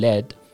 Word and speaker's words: let: let که خیلی وقتا اون تیش رو د تلفظ میکنیم میگیم led به